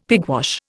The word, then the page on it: pigwash: Indian